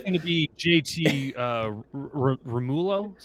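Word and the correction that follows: Realmuto: Real Muto. – Real Muto.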